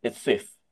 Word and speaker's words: SWTH: And to pronounce it, it's SWIFT. That's the pronunciation.